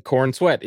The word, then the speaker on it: Corenswet: But David Corenswet, right? Yes, uh, actually, yes. The Corenswet is how you pronounce it.